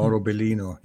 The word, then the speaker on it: Mauro Biglino: Mauro Biglino, yeah.